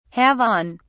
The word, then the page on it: have on: British English